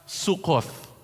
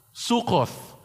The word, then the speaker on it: Sukkoth: But in Hebrew, the word booth is pronounced as Sukkoth. Sukkoth.